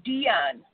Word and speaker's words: Dionne: Dion, how do you spell that? – D-I-O-N-N-E.